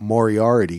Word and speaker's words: Moriarty: and I like what you said about Moriarty, because I think that's the way you pronounce his name, right? Like the Sherlock Holmes guy.